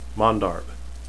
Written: mandarb

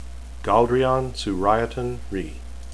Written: galldrian

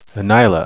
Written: enaila

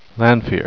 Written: lanfear

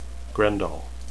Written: Graendal

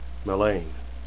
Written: melaine

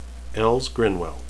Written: else

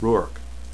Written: rhuarc

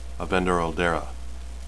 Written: avendoraldera

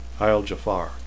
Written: jafar

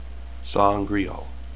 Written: saangreal

